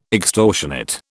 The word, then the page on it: extortionate: British English